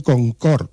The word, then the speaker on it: Concorde: Y el tercero en base al aforo, fue el cine Concord, se escribe Concorde, se construyó en el barrio de Orriol, pero se construyó muy tarde.